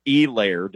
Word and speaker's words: Helaire: He's got 112 more than Clyde Edwards-Helaire, or Helaire, depending on how hard you hit that E, because the H is silent, Buck.